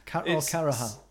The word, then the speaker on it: Carracher: But what is her surname? – Karaha. – Kara? – Karaha. – Karaha. – C-A-R-R-A-C-H-E-R. Don't search that online. That'd be weird.